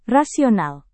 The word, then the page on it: rasional: Indonesian